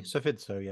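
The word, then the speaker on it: Psephizo: You may have seen Dr. Paul's work at his blog, Cefizo. I think I pronounced it right. – Yeah, nearly. Cefizo, yeah.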